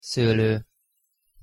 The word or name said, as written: szolo